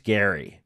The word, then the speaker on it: Gerry: it's, it's Jerry pronounced Gary, but it's spelled G E R R Y, pronounced Gary.